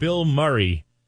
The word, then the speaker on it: Bilmuri: rock, new rock from a band called Bill Murray. It's spelled B-I-L-M-U-R-I. It's a play on words, obviously, for actor Bill Murray.